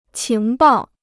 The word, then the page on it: 情报: qíng bào